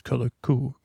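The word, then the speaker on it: Colorku: So we'll be playing Color Cue, which is that how you say it? It's Color Cue, K-U, my friend.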